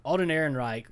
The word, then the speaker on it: Alden Ehrenreich: Alden Ehrenreich, there were rumors that he wasn't a good actor and they had to have like acting coaches and stuff.